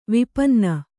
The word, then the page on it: ವಿಪನ್ನ: vipanna